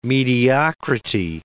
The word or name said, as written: mediocrity